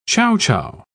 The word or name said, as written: chowchow